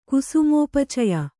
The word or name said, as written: ಕುಸುಮೋಪಚಯ